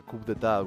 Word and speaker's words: Coup d'État: Muchos se preguntarán por qué no ha sonado, sin embargo, al frente de los Plasmatics, alguna canción de, su obra maestra, Coup d'État, golpe de Estado, pronunciado en francés.